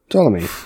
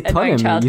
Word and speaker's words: Ptolemy: You don't say Ptolemy, you say Ptolemy.